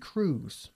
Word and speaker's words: Cruz: O como decimos en inglés, Penélope Cruz. – Penélope. – Igual que Tom Cruise, por cierto. Se pronuncian igual.